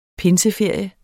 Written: pinseferie

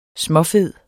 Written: småfed

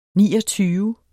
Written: NIOGTYVE

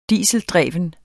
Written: dieseldreven

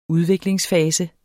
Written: udviklingsfase

